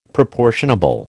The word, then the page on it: proportionable: American English